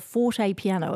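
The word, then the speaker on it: fortepiano: Christian, you often perform on what's called a forte piano. Am I saying that correctly? – That's correct.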